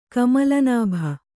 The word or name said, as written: ಕಮಲನಾಭ